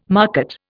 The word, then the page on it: market: Indian